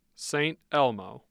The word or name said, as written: St. Elmo